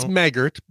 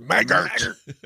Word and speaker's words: Maggart: And it's Maggart, you know, because he might listen. Maggart, send us an email and say it's pronounced Maggart. – Maggart, you darn Maggart. – Yeah. Looks like Maggot to me, but whatever.